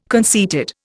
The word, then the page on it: conceited: Indian